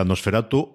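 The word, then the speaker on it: NOS4A2: Es Nosferatu, porque vamos a pronunciar a Nosferatu, aunque se escriba NOS 4A2,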